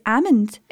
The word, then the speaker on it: almond: It is a silent L. – I change the way I say that. – American accents, they say almond, but it's almond.